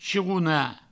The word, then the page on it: chironar: chirouna; provençal